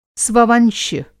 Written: स्ववंश्य

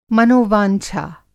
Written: मनोवांछा

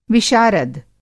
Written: विशारद

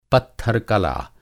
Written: पत्थर-कला

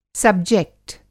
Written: सब्जेक्ट

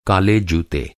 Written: जूता

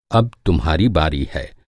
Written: बारी

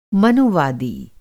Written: मनुवादी

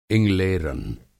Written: इंगलेरन

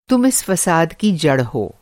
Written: फ़साद

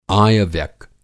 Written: आय-व्यक

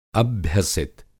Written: अभ्यसित

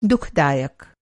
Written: दुःखदायक

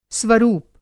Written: स्वरूप

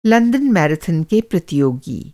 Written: मैराथन

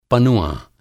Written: पनुआँ

